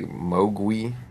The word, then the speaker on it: Mogui: The Mandarin pronunciation is, looks like Mogui. Mm. It's spelled M-O-G-U-I instead of W-A-I.